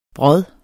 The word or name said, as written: brodden